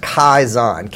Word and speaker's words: Kaizen: She also introduced this really interesting word that I've never forgotten, and I believe it's pronounced Kaizen, K-A-I-Z-E-N, and it's a Japanese term that means continuous improvement.